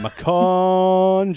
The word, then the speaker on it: Macon: Don't be confused with Brazilian football and Micon. We're in Macon, Georgia.